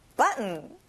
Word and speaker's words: button: 단추, button. 자, T 발음은 잘 안 나요.